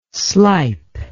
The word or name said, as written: slype